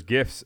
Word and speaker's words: GIF: He has all those, you know, those gifts of him literally, and it's pronounced GIF. It's not GIF. GIF is a peanut butter.